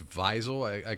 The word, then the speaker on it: Wiesel: Ely Wiesel? – I think it's Wiesel. – Wiesel? – Yeah. – And it's like we just flipped the W and the V? – I believe so.